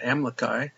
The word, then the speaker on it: Amlici: and you may be wondering why I would call him Amlici, not Amlici. It's pronounced Amlici. How do you know that? Well, in the back of the Book of Mormon, there's a pronunciation chart, and I looked it up, and it says Amlici.